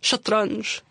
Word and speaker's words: shatranj: It's shatranj. Mm. You have to roll your R. It sounds really cool.